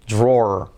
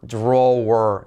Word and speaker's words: drawer: Fijaos, se escribe drawer, pero se dice drawer. Qué raro. La letra clave R. Drawer. No se dice where, sino drawer. Letra clave R y letra clave al final para rematar. Drawer.